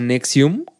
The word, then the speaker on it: NXIVM: Esta chava eh, es culpable de, todas esas madres por, colaborar una madre que se llama Nexium, que se escribe N-X-I-V-M, así como, ya sabes, como...